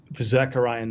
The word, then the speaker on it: Zechariah: we communicated earlier about uh, Zechariah. Did I say it correctly? – Zechariah? Yeah. Zechariah? – Okay.